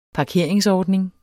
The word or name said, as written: parkeringsordning